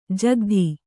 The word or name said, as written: ಜಕ್ಕಾತಿ